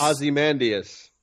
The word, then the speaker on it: Ozymandias: Is it Ozymandias or Ozymandias? – Do I pronounce it wrong? – It's Ozymandias.